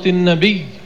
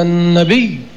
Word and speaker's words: النبي: كذلك في لفظة النبي لا يقال النبي بالتخفيف، إن الله وملائكته يصلون على النبي، على النبي مع لفظ الشدة.